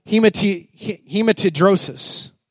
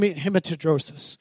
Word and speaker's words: hematidrosis: This is a, medical condition called hematidrosis. That's probably the only thing some of y'all are going to remember that I mispronounced it. Hem- hem- hematidrosis.